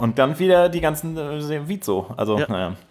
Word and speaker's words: WIZO: Den hätte ich gerne, dabei überlege ich mir die ganze Zeit, heißt diese Band, wird Vizo ausgesprochen oder Wieso? Warum gibt es Menschen, die Wieso sagen? W-I-Z-O ist doch Vizo, oder? – Ja, eigentlich schon, aber es gibt ganz viele, die sagen Wieso.